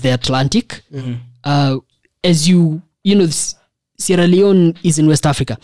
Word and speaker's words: Sierra Leone: Sierra de Leone. – Oh, that's how it's pronounced. – Sierra Leone,